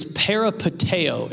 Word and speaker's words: peripateo: That word live there is actually the Greek word, it's peripateo is how you say it. Almost sounds like a pair of potatoes, but it's not that.